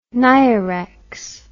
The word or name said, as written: nirex